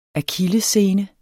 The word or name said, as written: akillessene